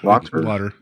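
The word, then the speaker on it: Lauter: Lauder.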